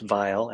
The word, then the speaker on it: Weil: A book by Simone Weil, pronounced Vile at the end. It's spelled Weil, Simone.